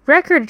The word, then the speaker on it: record: record 这个词不难，那我要提醒大家的是它的发音。如果重音位置在第二个音节 record，那么它就是动词，比如 to record all your expenses，记录所有的开支。